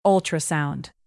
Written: ultrasound

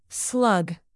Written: slug

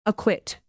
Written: aquit

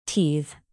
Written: teethe

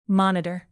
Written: monitor

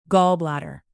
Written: gallbladder

